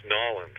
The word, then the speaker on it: New Orleans: And, and by the way, I like the way you pronounce Nolans. – Nolans, yeah. Danny, how do you pronounce it? New Orleans.